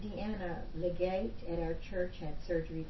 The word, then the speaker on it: Legate: Some people call it Legate, but it's spelled L-E. In capital G A T E, Legate.